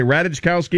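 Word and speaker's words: Ratajkowski: Well, a couple of white chicks, Vanessa Hudgens and Emily, how do you pronounce it? Radikowski. – Uh-huh. – What did I say? Radikowski? – Yeah, that's good. You're fine.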